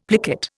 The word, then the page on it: plicate: Indian